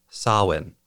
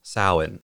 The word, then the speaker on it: Samhain: First, while the holiday I'm talking about is spelled as though it would be pronounced Samhain or Saman, it's actually pronounced Samhain or Samhain.